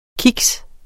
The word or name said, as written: kiks